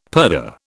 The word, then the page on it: purdah: British English